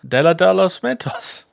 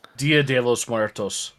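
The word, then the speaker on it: Día de los Muertos: It's Dia de los Muertos. – Dia de los Muertos. – That too. I knew I was saying it wrong, but I couldn't correct myself.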